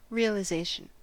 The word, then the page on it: realization: American English